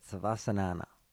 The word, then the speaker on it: Savasana: Savasana.